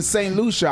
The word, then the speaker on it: St. Lucia: Now, I was in St. Lucia.